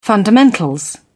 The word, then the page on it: fundamentals: British English